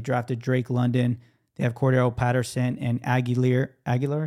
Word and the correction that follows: Allgeier: is that how you say his name? The running back? I can't remember. Allgeier. Allgeier, like yeah.